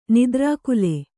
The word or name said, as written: ನಿದ್ರಾಕುಲ